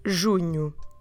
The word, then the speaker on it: junho: It's M-A-I-O, but in Portuguese, M-A-I-O. Several vowels, three vowels in a row, and we pronounce them maio. Maio. Junho. Junho. There's a N-H. N-H in the middle, and that makes the sound nh, nh, nh, nh, nh, nh, nh. So we go junho, junho, junho.